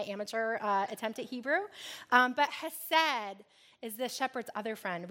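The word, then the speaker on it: hesed (חסד): I embarrassingly pronounced the Hebrew word for that whole sermon, chesed. It is hesed.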